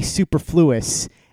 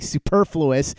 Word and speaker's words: superfluous: I used to say superfluous. And then one day I heard somebody say superfluous, and I was like, oh, that's how you say it.